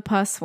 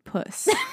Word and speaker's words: puss: So this is what I was calling it, the pus one. But I think it is supposed to be pronounced puss. Because they were saying it was named because it looks like a house cat.